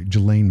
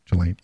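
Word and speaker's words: Ghislaine: So basically Jelaine, Jelaine Maxwell, Jelaine, even though it's spelled Jelaine Maxwell, her dad is the beginning of the begin, when it comes to all this.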